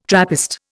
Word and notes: Trappist: Indian

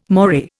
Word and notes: moray: Indian

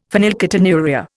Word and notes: phenylketonuria: Indian

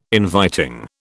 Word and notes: inviting: British English

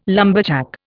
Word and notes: lumberjack: Indian

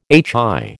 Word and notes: HI: American English

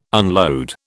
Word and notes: unload: British English

